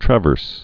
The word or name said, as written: traverse rod